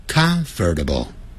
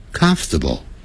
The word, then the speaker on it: comfortable: 不要念成 comfortable, comfortable. 相信我，老外就是这样念的。除非他是移民的人，还搞不清楚。 Native speakers of English，我讲的是在美国或者是加拿大，他们一定是念成 comfortable。相信我。好，今天我们这一课就讲这边啦。